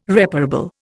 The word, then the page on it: reparable: Indian